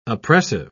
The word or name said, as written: oppressive